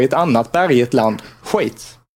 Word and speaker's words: Schweiz: Schweiz.